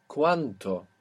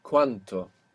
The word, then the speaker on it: cuánto: And how much is cuánto with a T, cuánto? – ¿Cuánto?